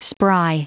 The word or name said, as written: spry